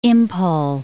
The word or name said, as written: Imphal